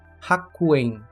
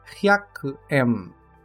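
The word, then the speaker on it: hyaku-em (百円): Outro erro bastante comum nessa pronúncia é que muitos acabam pronunciando como haku, principalmente quando vamos falar o valor em iene, que é a moeda do Japão, hakuen, sendo correto hyaku-em.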